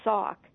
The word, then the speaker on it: Sac: And as I mentioned, Sac, which is sometimes pronounced Sauk, it means people at the mouth of a river.